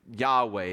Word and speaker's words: Yahweh: It's Yahweh is how they pronounce it.